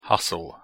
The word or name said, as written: hustle